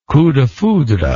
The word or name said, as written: coup de foudre